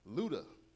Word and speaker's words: ludus: The next type of love, as we come around the mountain here, is ludus. Luda is how it's pronounced. No, it has nothing to do with ludicrous, y'all.